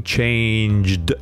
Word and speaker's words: changed: I've never changed my name, changed. This is a d pronunciation.